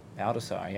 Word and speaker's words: Baldassare: Baldassare, yeah.